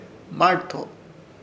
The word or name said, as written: Hmarto